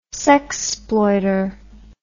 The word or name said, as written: sexploiter